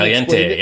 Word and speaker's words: caliente: And then caliente, yes. – And then explain that it means hot over. No, but they pronounce the L in the commercial.